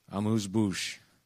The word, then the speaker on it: amuse-bouche: Amuse-bouche.